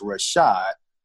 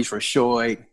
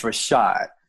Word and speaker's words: Rashoid: And my mom spelled it Rashoid. So in my bio on our website, I have in parentheses that is pronounced Rashad, Rashad, because sometimes people say Marquis Rashoid, but it's Rashad.